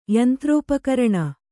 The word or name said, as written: ಯಂತ್ರೋಪಕರಣ